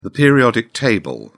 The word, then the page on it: periodic table: British English